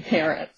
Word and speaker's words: Parrott: But I pronounce his name Parrott.